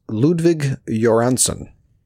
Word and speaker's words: Ludwig Göransson: His name is Ludwig Göransson, and he is known for, really, he kind of made a name for himself with his Black Panther score that he did.